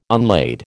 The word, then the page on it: unlade: American English